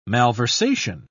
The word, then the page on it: malversation: British English